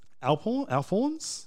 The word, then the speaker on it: Alphorn: Souvenir shops that sell Alphorns. You don't pronounce the H? – No. Alphorn? No, you do. Alp horns.